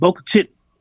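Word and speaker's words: Bogue Chitto: I don't even know how to spell it, but they call it Boca Chitty.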